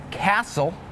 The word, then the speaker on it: castle: Some examples with a silent T are the words listen, the word castle, and the word Christmas.